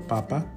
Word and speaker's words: papa: Estamos diciendo papa, potato, y no estamos utilizando la palabra que utilizamos para hablar de nuestro papá, father. Okay, we're using papa, potato, not papa, father.